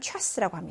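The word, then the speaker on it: 차스 (час): 초문자는 작게, 이 아이의 명칭은 최, 실제 발음은 취 되겠습니다. 그래서 시간이라고 할때 러시아로 차스라고 합니다.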